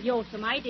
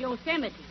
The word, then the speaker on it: Yosemite: It isn't Yosemite, it's Yosemite.